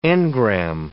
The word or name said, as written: engramme